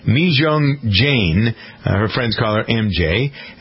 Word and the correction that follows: Jang: You almost got it right. My last name is Jang, Mijung Jang, but I go by MJ, so you can call me MJ. – All right. It's Jang, your last name.